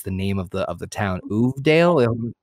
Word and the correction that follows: Uvalde: Uvalde, Texas. – Uvalde. Thank you.